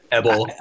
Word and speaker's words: Ebal: and then the curses on Mount Ebal. Or is that how you pronounce it there, Dr. Hebrew? – Yeah, Abel. – Sure. – Abel.